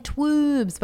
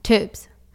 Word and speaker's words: tubes: And people freak out when they hear us say it, because they're like, oh my God, I've been saying twoobs. But we're like, no, like, get it, like two. And weirdly, only Americans can really say it properly, because they say tubes. – Yeah, like, so many people we work with say twoobs, and we're like, tubes. No, tubes. No, guys, it's tubes.